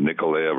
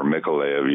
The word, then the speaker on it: Mykolaiv: And the Russians got very close to his city, and his city's a shipbuilding town, Nikolaev or Mykolaiv, you can pronounce it either way, on the north end of the Black Sea.